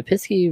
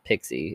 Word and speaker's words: Pisky: The Pisky runs, and I'm saying that exactly how it's spelled, not Pixie, it's Pisky, runs rampant, causing mischief and mayhem along its path.